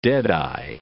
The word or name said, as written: deadeye